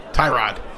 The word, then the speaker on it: Tyrod: how are we pronouncing his name these days? Tyrod or Tyrod? – Tyrod. – He's always going to be Tyrod Taylor to me, but Tyrod, a.k.a. Tyrod, according to Hard Knocks.